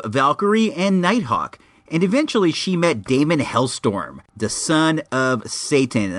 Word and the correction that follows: Hellstrom: And I said that wrong. I do it every time. It is Hellstrom. Damien Hellstrom, not Storm, even though either one is dope.